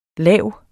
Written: lav

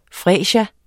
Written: fresia

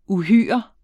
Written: uhyre

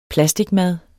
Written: plasticmad

